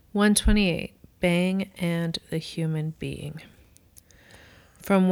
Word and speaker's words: Beyng: And a reminder that when we say bang, we are pronouncing B-E-Y-N-G, which is Heidegger's alternative spelling of being, B-E-I-N-G.